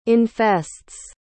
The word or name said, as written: infests